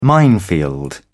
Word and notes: minefield: British English

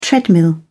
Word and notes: treadmill: British English

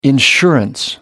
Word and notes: insurance: American English

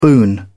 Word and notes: boon: British English

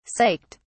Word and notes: psyched: British English

